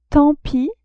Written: tant pis

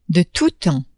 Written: de tout temps

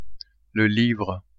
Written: livre

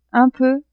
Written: un peu

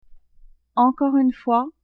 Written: encore une fois

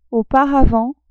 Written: auparavant

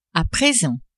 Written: a present

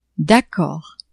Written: d'accord

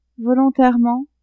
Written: volontairement

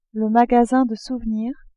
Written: magasin de souvenirs

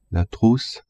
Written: trousse

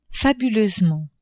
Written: fabuleusement

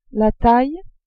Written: taille

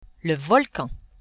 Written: volcan

